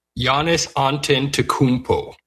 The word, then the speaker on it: Antetokounmpo: Giannis Antetokounmpo. That's his. That's how you pronounce it.